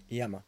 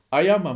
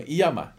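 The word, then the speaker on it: Iiyama: İyama. – Ayama mı? İyama. İki tane i ile başladığı için Allah'ın belası bir isim. Hani söyle nasıl söyle telaffuz edeceğini bilemiyorsun.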